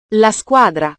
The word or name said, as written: la squadra